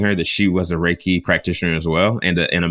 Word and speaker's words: Reiki: Reiki. – R-A-Y-K-E-Y. – That's a perfect way to pronunciation. Perfect. – Reiki.